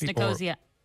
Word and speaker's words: Nicosia: Is it Nicosia, which I'm probably not pronouncing correctly? – Yes, it's Nicosia.